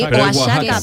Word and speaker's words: Oaxaca: Sí, Oaxaca, pero se escribe Oaxaca. – Eso. – Exactamente, con J, pero se escribe con X.